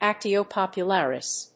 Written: actio popularis

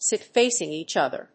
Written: sit facing each other

